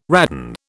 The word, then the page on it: radon: British English